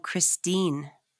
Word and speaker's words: Christine: No, I'm Christine.